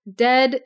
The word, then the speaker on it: Ded: Dead is how you pronounce the D-E-D, like, his name.